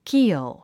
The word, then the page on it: keel: kíːl; キーォ